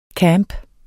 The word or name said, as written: camp